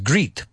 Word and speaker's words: great: Y no lo pronuncias great, por favor. Es great. Suena como ocho. ¿No? Eight. Suena como el pasado de comer.